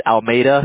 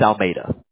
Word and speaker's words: Almeida: How do you say it? – It's Almeida. It's Portuguese, so okay. Yeah, not a lot of people can say it correctly, but it's Almeida.